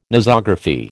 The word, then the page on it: Nosography: American English